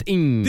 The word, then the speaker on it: Ng: NG, but pronounced ing.